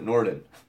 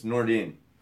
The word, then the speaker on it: Nordin: Why did I pronounce it Norden? It's Nordin. It's easy to read it as Norden, but it's Nordin.